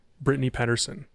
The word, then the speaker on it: Brittany Pedersen: He's also the husband to presumptive Democratic candidate for Congress, Brittany Pedersen. What district is it? 7? – You pronounced both of our names correctly, which is like a thing that never happens.